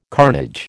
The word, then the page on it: carnage: American English